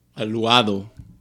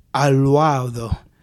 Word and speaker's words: Aluado: Today we're going back to a classic, a very delicious bottle of red wine by the name of Aluda. – Aluado. – Aluado. – Aluado Alicante Boshi. – Which is spelled, for any of our Braille listeners, A-L-U-A-D-O. – Where does this wine come from? – This wine is from Portugal.